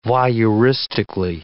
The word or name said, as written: voyeuristically